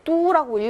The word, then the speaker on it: 都: 난징이란 도시는 중국 구도의 뚜라고 읽어야죠. 수도 할 때도, 고도 할 때도 뚜라고 읽습니다.